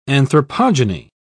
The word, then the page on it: anthropogeny: British English